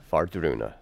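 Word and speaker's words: Wardruna: So there you have the W. But of course, it's not common, and we pronounce it as a V. – As a V, right. – Yeah. – Vardruna starts with a W.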